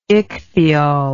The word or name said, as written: ichthyol